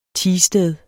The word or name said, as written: teested